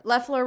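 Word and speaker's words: Loeffler: Leffler rhymes with theft.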